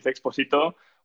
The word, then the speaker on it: Expósito: my last name is Expósito, which I have to say, I understand is a pretty tricky pronunciation for especially a Swedish country and Swedish speakers.